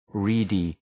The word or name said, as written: reedy